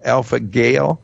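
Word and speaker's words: Alpha-gal: Alpha Gal. Is that correctly pronouncing that? G-A-L. Alpha Gal Syndrome. – Alpha Gal. Yeah.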